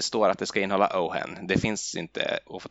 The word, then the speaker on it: Ojen: Men jag vet inte, känner du till, eh, känner du till en sprit som heter Ohen? Nej. Stavas Ojen. O-J-E-N. Mm. Ohen, det ska tydligen uttalas Ohen, återkommer gång efter annan i dessa böcker också.